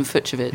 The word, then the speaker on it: Fucsovics: I went to lengths to ascertain how to correctly pronounce his name. So there you go, Martin Fucsovics.